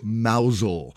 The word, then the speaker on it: Mousehole: Now, I know it's pronounced Mousel, but if you look at it in text, it's spelled Mousehole.